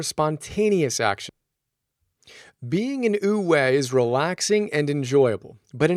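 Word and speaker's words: Wu Wei (无为): Wu Wei, again, W-U-W-E-I, pronounced U, kind of like U, Wei, and Da, spelled D-E, which is one of the coolest words ever, that is, unfortunately, pronounced Da.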